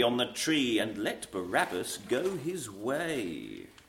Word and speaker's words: Barabbas: Whither of them will ye have, Christ Jesus or Barabban? – I think it must be Barabbas. – No, Barabbas, yes. Barabbas. Yes, it's just, for some reason it's spelled that way. Just it's presumably to make it rhyme.